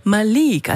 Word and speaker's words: Malik: Malik, also Betonung liegt auf der zweiten Silbe, Malik Harris.